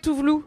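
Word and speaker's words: Tove Lo: Ah oui, je confonds avec Touvelou, qui s'écrit Tovelo.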